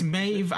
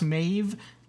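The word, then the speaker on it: Maebh: I got in trouble with this because I was saying Maeve because it's spelled with a B. – It's Maeve. – I think I think it's pronounced Maeve, like as if it was a V, as in Vincent, but it's a B, as in boy. It's M A E B H, which threw me off, and that's why I was calling her Maeve.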